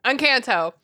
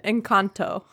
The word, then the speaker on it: Encanto: Encanto. – Someone's the sorry, that was the extremely white pronunciation. Encanto.